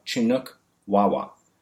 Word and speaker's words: Chinook Wawa: He loves basketry, playing the banjo, and is a fluent speaker of Chinook Wawa.